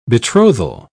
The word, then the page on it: betrothal: British English